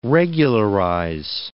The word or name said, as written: regularize